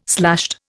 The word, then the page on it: slashed: Indian